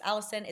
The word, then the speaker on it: allicin: It's called allicin, not spelt like the name allicin, although I guess some people may spell their name like this, but it's pronounced allicin.